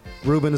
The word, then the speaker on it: Ruben: I am Ruben Paul. Ruben is spelled R-U-B-E-N.